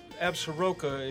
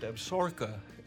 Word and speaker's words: Absaroka: And it's about the Absaroka is actually pronounced Absorka, and that's the name that the Crow Indians refer to their nation.